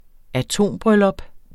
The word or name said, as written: atombryllup